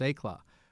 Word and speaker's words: Eclat: It's spelled ECLAT, but phonetically, at least, you wouldn't pronounce it that way. So it was a French name, which stood for Brilliance of Success, and the correct pronunciation was ECLAT.